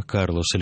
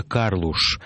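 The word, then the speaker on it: Carlos: всюду был Антонио Карлос, или, как его бразильцы называют, Антонио Карлуш Жобим.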